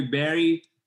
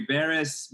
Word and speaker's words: Barış: My normal name in United States, I live in United States, they call me Barry because it's easier to pronounce. In Europeans, they call me Barris because it's how it's written there. And the actual name is, I'm originally from Istanbul, Turkey, and the original name is actually Barış.